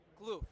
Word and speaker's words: Kloof: And I've just come across Kloof, do you pronounce it Kloof? – Kloof, yeah.